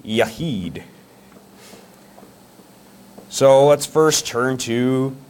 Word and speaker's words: Yachid: And I actually looked up Hebrew pronunciation, and so I wouldn't embarrass myself up here mispronouncing these words, but that's actually how you pronounce it. And that is spelled Y-A-C-H-I-D, Yahid.